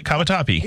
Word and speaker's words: Cavatappi: Am I saying it right? – Yeah, it is. I spelled it a little bit wrong, but yeah, no, you got it. Cavatappi.